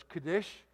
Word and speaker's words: Kadesh: In the Hebrew, the last syllable is usually the strongest. And so sometimes I mispronounce these things. But it's Kadesh and it's in the hill country of Naphtali.